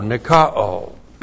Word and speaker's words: nikao: N-I-K-A long O, nikao.